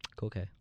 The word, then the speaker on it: Koke: And I'm going to say his name wrong, but Koke or Koke? – Koke.